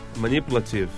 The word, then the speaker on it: manipulative: Aonde tá a sílaba tônica? – Manipulative. – Manipulative.